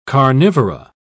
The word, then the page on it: carnivora: British English